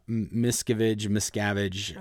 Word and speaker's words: Miscavige: Did I say his name correctly? – Yeah, Miscavige. Okay. He pronounces it Miscavige.